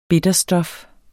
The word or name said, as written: bitterstof